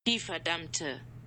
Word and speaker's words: Die Verdammte: How did I say it again? Die Verdammt? – Yeah. – Die Verdammt. – We'll see if this... – Die Verdammte.